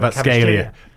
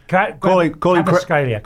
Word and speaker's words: Kvaratskhelia: Kvaratskhelia. – Kvaratskhelia.